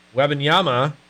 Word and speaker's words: Wembanyama: Wembanyama. There we go. I said it right now.